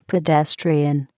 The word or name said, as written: pedestrian